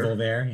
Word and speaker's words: Envolver: The reference was to the song Envolver, or I think it's pronounced Envolver. – Envolver, yeah.